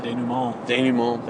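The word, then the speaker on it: dénouement: A revenge, you know, the sort of, like, the climax or the denouement, how do you say that? – Denouement, thank you.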